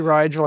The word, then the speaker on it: Rigel: So for example, brightest star in the constellation Orion, R-I-G-E-L.